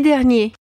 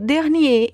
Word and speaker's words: dernier: E aqui vale a pena a gente atentar para a diferença de pronúncia entre o masculino dernier e o feminino dernière. Eu sinto que os alunos de francês sempre tendem a falar dernière para tudo. Mas quando a palavra for masculina, a gente tem que dizer dernier.